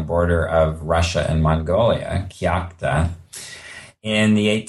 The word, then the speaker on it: Kyakhta: and I'm going to butcher the pronunciation, Kyakhta. – Kyakhta, right. – Kyakhta.